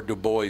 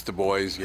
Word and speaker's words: Du Bois: Here, South it is Du Bois. – It's not Dubois, it's Du Bois.